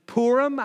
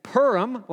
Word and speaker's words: Purim: People pronounce this in English, Purim. I think that would be the most common, like P-O-O-R, Purim, Purim. Some would say Purim, like a cat purrs, Purim.